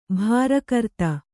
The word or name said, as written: ಭಾರಕರ್ತ